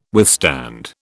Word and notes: withstand: British English

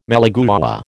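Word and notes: malagueña: American English

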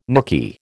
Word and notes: nooky: American English

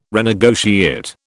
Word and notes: renegotiate: British English

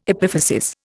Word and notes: epiphysis: Indian

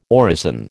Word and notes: orison: American English

